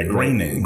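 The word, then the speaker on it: Groening: Matt Greenig, right? – Yeah, Greenig.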